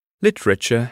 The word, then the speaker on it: literature: For example, literature has 3, not 4 syllables.